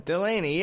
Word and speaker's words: Delaney: Delaney, yeah. Perfect. That's me. – Okay, like the comedian, right? John Delaney? – Yeah, pretty much.